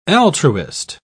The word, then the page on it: altruist: British English